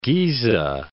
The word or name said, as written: Giza